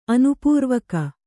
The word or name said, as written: ಆನುಪೂರ್ವಕ